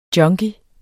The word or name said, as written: junkie